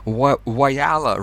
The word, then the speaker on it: Whyalla: I'm in Vilnius, Lithuania, and you in Whyalla, right? Am I pronouncing the name of your town correctly? – Very good. Well done.